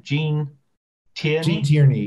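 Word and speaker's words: Gene Tierney: So this leads us to Jean Tierney, who is am I mispronouncing her name? Jean Tierney, yep, you're correct.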